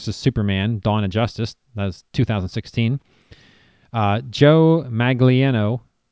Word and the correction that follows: Manganiello: I wrote this down so I could get the... – How dare you mispronounce that name. – Joe Manganiello. Joe Manganiello.